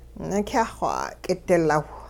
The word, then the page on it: nikyah-xw 'a'k'idilaw: Hupa